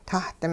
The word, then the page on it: tah-ding: hupa